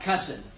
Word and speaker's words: cussin: In the South, we just talk about cussing. – En el sur, nosotros simplemente hablamos como groserías, pero rima con la palabra primo. – Spelled C-U-S-S-I-N.